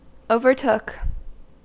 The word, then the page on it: overtook: American English